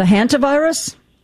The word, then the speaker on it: Hantavirus: The Hansu virus? I'm not sure what that is. Is that how you pronounce it? – The Hantavirus? – Oh, Hantavirus, mm-hmm.